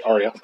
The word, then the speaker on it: Arya: I really enjoyed the interaction between Arya. – Mm-hmm. – I can't pronounce her name right now, but anyways. – Yeah, that's it, Arya.